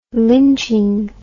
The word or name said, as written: lynching